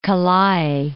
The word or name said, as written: Ka Lae